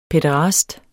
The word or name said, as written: pæderast